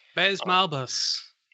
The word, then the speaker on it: Baze Malbus: Baze Malbus.